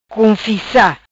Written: Cunfissa

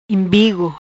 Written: Imbigo

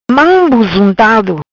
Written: Buzuntado Mam

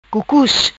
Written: Cucus

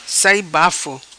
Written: Sai Bafo

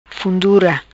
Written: Fundura

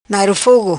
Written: Nairo Fogo

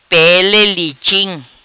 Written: Pele Liching

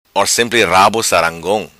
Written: Sarangong Rabo

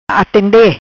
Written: Atende

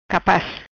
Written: Capaz

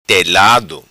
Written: Telado

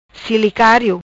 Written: Cilicario

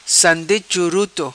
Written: Sande Churuto

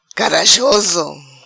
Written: Carajoso

